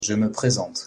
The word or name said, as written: je me présente